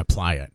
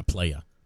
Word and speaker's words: playa: And we had to explain to Charlie that it's crash my playa, not crash my playa. Like, Charlie, it's not playa.